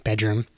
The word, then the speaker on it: BDRMM: Now, it's not self-titled because they are BDRMM Bedroom, and the album is spelled out Bedroom.